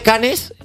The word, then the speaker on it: Cannes: O sea, se escribe Canes, sí. Pero no es Cans.